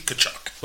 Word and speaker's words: Tkachuk: Tkachuk doesn't spell that way. It looks like I believe. – Yeah, not at all. The T is silent. So yeah. It's Keith Tkachuk.